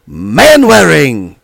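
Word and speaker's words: Manwaring: Kurt Man Wearing. – You're right. Very good. That's not a bad nickname either. His last name is spelled... No, I mean it's pronounced Man Wearing.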